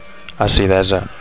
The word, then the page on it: acidesa: Catalan